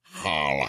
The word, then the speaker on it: challah: It's pronounced challah.